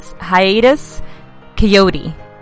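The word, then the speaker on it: Hiatus Kaiyote: It is Hiatus Coyote. And it is not a real word. It is spelled K-A-I-Y-O-T-E because we all really want you to go check them out.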